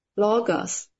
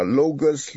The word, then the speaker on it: Logos: ？Logos。 – 对，L-O-G-O-S，你爱怎么念就怎么念啊，Logos、 Logos、 Logos、 Logos 都可以。